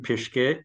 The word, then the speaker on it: Pischke: Well, my full name is Jon Steffen Pischke.